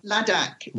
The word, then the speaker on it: Ladakh: Ladakh. Ladakh is the area, and Leh is the town.